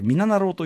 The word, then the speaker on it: 3776: 3776 と書いて、みななろうというですね、